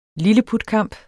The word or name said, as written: lilleputkamp